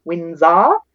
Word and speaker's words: Winzar: So you can find me most of the time on Facebook, Lauren Windsor, Decluttering and Organizing Coach. My last name is spelled W-I-N-Z-A-R, Windsor. You can also find me on Instagram, just my name, Lauren Windsor.